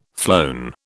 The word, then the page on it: flown: British English